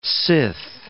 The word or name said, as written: sith